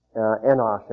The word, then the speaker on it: Enosh: Enosh, actually, is the Hebrew pronunciation.